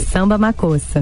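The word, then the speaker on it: Samba Makossa: Como é que se pronuncia o nome dessa música? – Samba Macossa.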